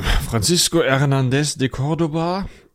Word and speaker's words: Francisco Hernández de Córdoba: 1517 äh, kam ein Francisco, oh, das wird unangenehm, Francisco Hernández de Córdoba, wenn ich das richtig ausgesprochen das habe.